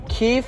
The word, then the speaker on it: Kief: For the listeners that don't know, Kief, which is in actuality pronounced, well, not pronounced, but spelled K-I-E-F. – Not K-E-E-F-E. – Or Kief. K-E-E-F.